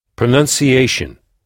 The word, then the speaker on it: pronunciation: Pronunciation, qui s'écrit avec UN et non pas ON comme en français. Donc, PRONUNCIATION, qu'on prononce pronon, pronunciation. Donc, on frappe la syllabe qui vient juste avant la terminaison shun.